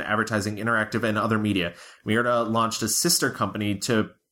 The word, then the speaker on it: Mirada: In 2010, he launched Miranda Studios, was longtime cinematographer, Guillermo Navarro, director Matthew Cullen, and executive producer Javier Jimenez. Mirada, did I say it wrong the first time? Maybe I did.